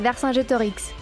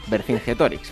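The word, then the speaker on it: Vercingétorix: Me retaba a que dijese Vercingetorix en francés. ¿Cómo se dice en francés? – Se dice Vercingetorix. – O sea, que no tiene nada que ver, ¿eh? Vercingetorix para los cristianos.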